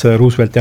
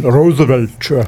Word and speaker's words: Roosevelt: President Roosevelti teemadel, sest ta vaatas Roosevelti jältuse peale väga juhmine aga otsa ja siis küsis, et do you mean Roosevelt? – Roosevelt. – Ja siis muigas, et kuidas poiss võib nii kündimatult hääldada, aga mul mingisugune asi turgatas kohe pähe, et noh, vaata mingi Texase puhul saab, eks ole, Texas mm-hmm.